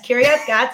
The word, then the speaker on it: Kiryat Gat: And you played for, I want to say the name right, Kiryat Gat and then Ramat Hasharon. – Close. Yes, Kiryat Gat, you did that one good.